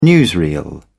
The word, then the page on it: newsreel: British English